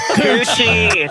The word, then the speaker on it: Kuchy: K-U-C-H-Y. How do you say it? – K-U. – Couche. – Couche. Couchki. – Couch. – Couchki. That's right.